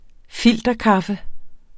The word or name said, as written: filterkaffe